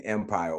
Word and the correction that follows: umpire: all week. I knew I was saying umpire. I was reading it, but I'm pronouncing it with an E.